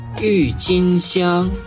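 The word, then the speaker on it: Юйчинсян (郁金香): Юйчинсян. – Это тюльпаны. У тюльпанов есть красные, розовые, желтые, фиолетовые, невероятно красивые. – Юйчинсян. – Юйчинсян. Тюльпан. Ю читается четвертым тоном, тин – первым тоном, сян – первым тоном.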